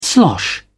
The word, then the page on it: slosh: British English